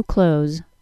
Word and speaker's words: clothes: The adjective, C-L-O-S-E, close. Keep close to your mother. Very good. Now, if you're a purist and want dictionary-like pronunciation, you can stick with trying to include the TH sound in the word clothes.